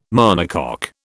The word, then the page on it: monocoque: British English